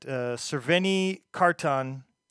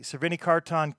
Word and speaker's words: Crveni Karton: And if you like the band, they're at Crveni Karton Let's see. Srveni Kartan KS on Facebook and at Srveni Kartan Official on Instagram. And that is spelled C-R-V-E-N-I-K-A-R-T-O-N.